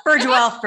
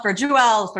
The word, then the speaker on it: Furgiuele: Furgiuele, Furgiuele, Furgiuele.